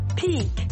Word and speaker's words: pique: Pique, to pique. Does this topic pique your interest? Ce sujet vous intéresse-t-il? Tournons-nous vers un autre mot prononcé de la même manière, le mot peak, sommet.